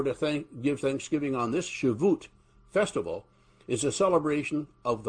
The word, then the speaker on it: Shavuot: They came to celebrate this annual Shavuot, is how it's said in Hebrew. Shavuot, or the Feast of Harvest, or the Pentecost.